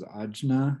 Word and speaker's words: Ajna: So, third eye chakra in Sanskrit is called Ajna, which is spelled A-J-N-A. I always thought it was Ajna, and then I heard someone correct Sanskrit, and they're like, no, Ajna.